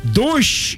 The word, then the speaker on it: дождь: В 19 веке, по-моему, как говорить «дождь» и «булочная» было булочная, признаком невероятного аристократизма.